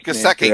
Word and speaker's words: Gesicki: I think I'm pronouncing his name Gesicki.